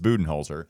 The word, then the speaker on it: Budenholzer: It's Budenholzer.